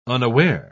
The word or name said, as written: unaware